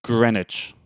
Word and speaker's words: Greenwich: It's spelled Greenwich, but I know that's not it. – It's Greenwich. – Yeah, like Greenwich Village in New York, right? – That's exactly correct, yeah.